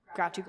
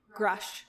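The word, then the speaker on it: Grusch: For the listener, it is still Grouch, but Grouchy Grouch. – Grouch. Did I say it wrong? Grouch? Say it again. – Grush.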